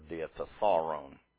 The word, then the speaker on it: Diatessaron: T-A-T-I-N. We'll call him by his anglicized name. We will not anglicize the word Diatessaron. I don't know how others pronounce it, but this is the way it should be pronounced in Greek.